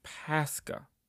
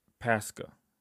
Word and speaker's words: Pascha: The transliterated word is Pascha. And that's getting the closest pronunciation and the closest word from the Greek to the English. Pascha.